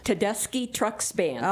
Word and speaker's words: Tedeschi Trucks Band: Tedeschi Trucks Band.